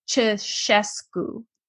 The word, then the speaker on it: Ceaușescu: under Ceaușescu. Did I say that right? – Yes. That yes. was very, very good. – Okay, Ceaușescu.